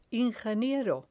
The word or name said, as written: ingeniero